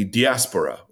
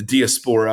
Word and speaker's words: diaspora: And they existed in something called the diaspora, or the diaspora, I've heard it pronounced both ways,